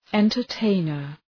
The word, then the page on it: entertainer: ,entər’teınər